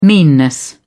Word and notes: meanness: British English

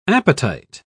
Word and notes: apatite: British English

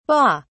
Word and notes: BA: British English

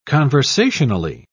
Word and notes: conversationally: British English

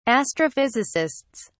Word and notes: astrophysicists: British English

